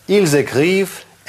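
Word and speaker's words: écrivent: Это все равно не окончание вот это из трех букв никогда не читается нигде. Ни в правильных, ни в неправильных. «Иль зэкрив», «эль зэкрив».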